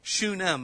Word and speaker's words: Shunem: Shunem, that's how you say it.